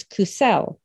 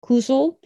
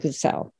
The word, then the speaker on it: Cusell: My last name is Cusell, like the coo of a bird, and sell, like you're selling something. Often mispronounced as Cusell or Cosell, but it's Cusell.